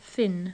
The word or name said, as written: Finn